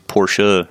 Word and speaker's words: Porsche: Porsche. Yeah, the correct pronunciation for the Porsche enthusiasts.